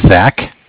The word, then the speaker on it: Zach: My name is Zach, spelled Z-A-C-H. – Okay. – And I'm from Bank of Cardiff. Cardiff is spelled C-A-R, like car, D as in dog, I as in imagination, F-F as in Frank.